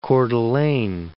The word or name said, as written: Coeur d'Alene